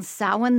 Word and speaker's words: Samhain: S-A-M-H-A-I-N. How do you pronounce that? – Samhain.